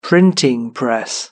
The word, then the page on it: printing press: British English